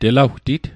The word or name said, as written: telawtit